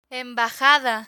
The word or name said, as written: embajada